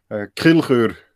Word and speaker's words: Kilchör: Kilchör. – Yeah, I can't pronounce that. Okay. – In Swiss German, yes. Even the Germans cannot pronounce it correctly. Only the Swiss people.